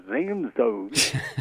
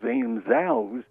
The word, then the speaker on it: Zamzos: And our name of our company is called Zamzos. It's a German word, and people would mispronounce it and call it Zamzows.